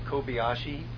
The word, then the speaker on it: Kobayashi: Did you pronounce it Kobayashi? – Yeah. – Probably A-S-H-I. – Probably S-H-I, yeah.